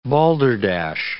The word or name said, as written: balderdash